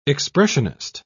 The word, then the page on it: expressionist: British English